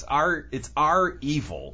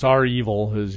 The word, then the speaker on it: REvil: I don't know if they're pronounced Revil or Revil, like that. It's our evil. – I think, yeah, it's our evil.